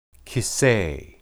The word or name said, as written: kisse